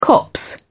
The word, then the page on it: copse: British English